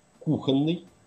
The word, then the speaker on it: кухонный: написано, что надо говорить апостроф, вероисповедание, жерло, зубчатый, инженерия, кровоточить, кухонный, ободрить, сливовый, ходатайство, исчерпать, исчевель.